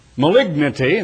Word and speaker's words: malignity: Murder, debate, deceit, malignity, and that is the way to pronounce it, not malignity, malignity.